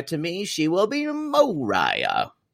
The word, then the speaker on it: Moriah: The star is, of course, Moriah. And I call her Moriah because her name is spelled Moriah. – That's not Mariah, y'all. That's Moriah.